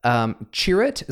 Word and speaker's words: Chirrut: When all hell is breaking loose, Chirrut, is that how you pronounce his name? – Yep.